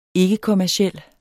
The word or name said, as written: ikkekommerciel